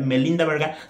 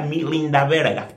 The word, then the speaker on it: Melinda Verga: Y que si lo pronunciara como lo pronunciarían en inglés, en lugar de ser Melinda Verga, sería Milinda Verga, tal cual, eh, tal cual es porque me acuerdo.